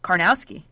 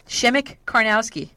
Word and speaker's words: Karnowski: Karnowski, that's how you say it. Someone said you don't think you're pronouncing it. Guess what? I did research on this, buddy. Shemek Karnowski, that's how you say it.